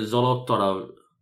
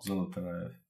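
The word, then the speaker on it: Zolotaryov (Золотарёв): And Alexander Zolotaryov, I didn't say that right at all. – Zolotaryov. – Oh, that's pretty good.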